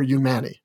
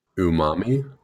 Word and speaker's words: Umami: Is it Umami or Umani? – Umami. I think it's Umami.